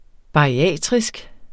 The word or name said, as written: bariatrisk